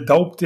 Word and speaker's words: Doubt: Auf jeden Fall ähm, hat sie mir Daubt empfohlen. Oder Dabt. D-O-U-B-T. Daubt, Daubt ausgesprochen.